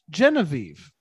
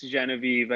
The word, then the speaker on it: Genevieve: Genevieve is a French name. That isn't at all pronounced Genevieve.